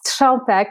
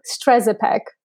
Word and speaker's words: Strzępek: So I was born in Poland, but raised in Canada. It's Strzępek, and I'm sure there will be Polish people that will say that I even say it wrong, but I adjusted to the culture. So in Canada, it was Strzępek.